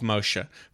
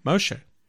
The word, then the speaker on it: Moshe: We've got the law of Moshe. For those of you who don't know. – Yes, please. – It's how you actually pronounce Moses' name in the Hebrew. That's his real name, Moshe.